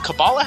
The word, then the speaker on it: Kabbalah: Is Kabbalah how it's pronounced? – Yeah.